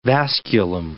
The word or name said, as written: vasculum